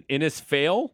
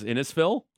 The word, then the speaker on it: Innisfail: Dave in Innisfail. Mm-hmm. Am I saying that right? – Innisfail? – Innisfail? – Yeah, you just kind of, you got to zip through it.